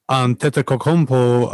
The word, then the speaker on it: Antetokounmpo: I'm going to attempt to pronounce this dude's, well, the first name, Giannis, but the second name, Antetokounmpo.